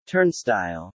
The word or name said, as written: turnstile